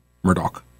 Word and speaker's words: Murdaugh: This for the trial of Alex Murdaugh. Murdaugh. Who I think should be convicted just because his name is spelled one way and everybody is pronouncing it in a way that it is not spelled.